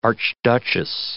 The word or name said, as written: archduchess